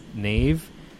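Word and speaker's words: Niamh: And one of their one of the host name is Neve, but it's spelt.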